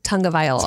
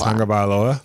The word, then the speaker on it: Tagovailoa: They always say it's Tagovailoa. – Tagovailoa. – Yeah, that's how I always remember to say that one.